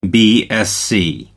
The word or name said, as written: BSc